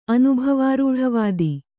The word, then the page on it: ಅನುಭವಾರೂಢವಾದ: anubhavārūḍhavāda